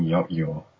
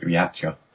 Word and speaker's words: Yautja: Steve Perry said it rhymes with lockjaw, so I'm guessing yot-yaw or something. I used to pronounce it yatcha.